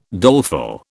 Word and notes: doleful: British English

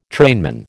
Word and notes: trainman: American English

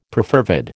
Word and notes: perfervid: American English

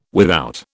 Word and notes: without: British English

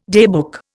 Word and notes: daybook: Indian

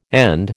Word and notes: and: American English

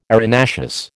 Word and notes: arenaceous: American English

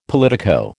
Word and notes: politico: American English

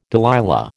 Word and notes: Delilah: American English

